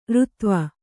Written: ಱತ್ವ

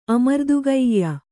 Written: ಅಮರ್ದುಗೆಯ್ಯ

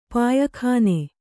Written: ಪಯ್ಕನೆ

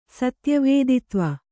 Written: ಸತ್ಯವೇದಿತ್ವ

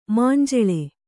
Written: ಮಂಜುಲ

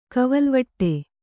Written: ಕವಲ್ವಟ್ಟೆ

